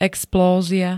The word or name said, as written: explózia